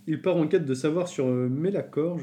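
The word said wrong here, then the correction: Malachor: je sais pas si je le dis bien, ou Melachor. – Malacor, moi je... – Malacor ouais,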